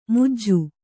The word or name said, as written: ಮುಜ್ಜು